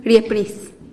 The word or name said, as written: riepnis